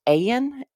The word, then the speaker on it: Ayan: I believe it's pronounced Ayan, A-Y-A-N, Ayan. So Jeff and Shalia Ayan.